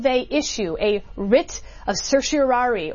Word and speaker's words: certiorari: And I'm not going to pronounce the whole word. – I'm going to certiorari. – There we go. You nailed it.